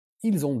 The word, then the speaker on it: ils: Ils ont deux enfants. Ils. Okay. So if it's alone, you don't pronounce the S, but if it's connected, then it becomes a Z.